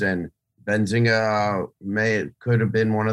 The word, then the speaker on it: Benzinga: I'm sorry, I didn't mean to cut you off, but you pronounced it great. Some people forget the N and go Benzinga, Benzinga, and I get annoyed.